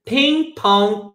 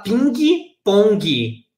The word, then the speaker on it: ping pong: With billiards and ping pong tables. Você não vai falar ping pong, é ping pong.